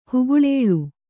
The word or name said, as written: ಹುಗುಳೇಳು